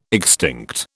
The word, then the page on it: extinct: British English